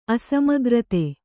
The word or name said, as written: ಅಸಮಗ್ರತೆ